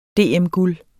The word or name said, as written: DM-guld